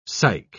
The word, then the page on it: psych-: British English